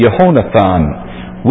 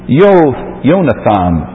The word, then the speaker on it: Yehonathan: And it's pronounced Yehonathan. Yehonathan. We have shortened it to Yonathan. And it is sometimes written that way, Yonathan, and it comes down to us as the word Jonathan.